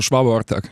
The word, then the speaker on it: Svaba Ortak: Ja, genau, hast du, Svaba Ortak.